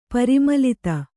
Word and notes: ಪರಿಮಿಲಿತ: parimilita

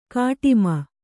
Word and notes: ಕಾಟಿಮೆ: kāṭime

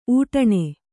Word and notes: ಊಟಣಿ: kannada

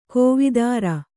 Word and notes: ಕವಿತರು: kavitaru

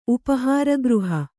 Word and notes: ಉಪಹಾರಗೃಹ: Kannada

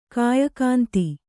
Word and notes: ಕಾಯಕಾಂತಿ: Kannada

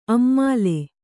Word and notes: ಅಮ್ಮಾಲೆ: Kannada

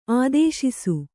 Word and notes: ಆದೇಶಿಸು: ādēśisu

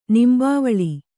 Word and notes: ನಿಂಬಾವಳಿ: kannada

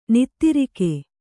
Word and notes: ನಿದ್ರೆಗೈ: nidregai

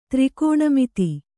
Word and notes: ತ್ರಿಕೋಣಮಿತಿ: tri kōṇa miti

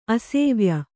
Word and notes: ಅಸೇವ್ಯ: asēvya

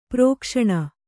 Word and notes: ಪ್ರಕಾಶನ: prakāśana

